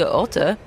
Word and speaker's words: Otte: Roger Federer 4-3-4 against Oscar Otte. I was taught that pronunciation by Boris Becker. I can't now not say it in a comedy German accent.